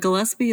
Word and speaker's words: Gillaspie: And the Gillaspie is not spelled like dizzy.